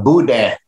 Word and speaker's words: Boudin: And I look forward to more Nightly Nuges and more Etouffee and Bodan. Is that how you pronounce it? Bodan? Is that that sausage? – Man, it's some Boudin. – Boudin.